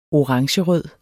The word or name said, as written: orangerød